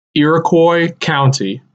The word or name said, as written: Iroquois County